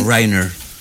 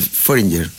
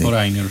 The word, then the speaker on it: Foreigner: ¿Cómo se dice? – Foreigner. – Ah, ese que yo decía Foreigner. – Eso. – Ese. – Foreigner.